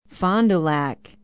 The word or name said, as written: fond du lac